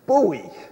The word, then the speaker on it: buoy: I said, yeah, the boy's ringing the bell. Well, I mean, let me just tell you, the correct pronunciation is buoy, not boy out there ringing the bell.